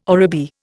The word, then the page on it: oribi: Indian